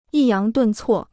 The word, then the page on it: 抑扬顿挫: yì yáng dùn cuò